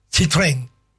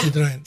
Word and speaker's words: Citroën: Citroën es Citroën. No es Citroën.